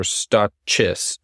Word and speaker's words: Stachys: It's probably Stachys. S-T-A-C-H-Y-S. It's not Stachys or Stachys, but Stachys.